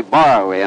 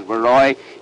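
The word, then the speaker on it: Borowy: The cab driver that I came out here with insisted on calling Baroy Borrowy, and I told him no, that the name properly pronounced was Baroy.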